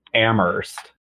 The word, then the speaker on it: Amherst: So the correct pronunciation is Amherst when you're discussing Massachusetts. – I don't think I can say that. Let me try. Amherst.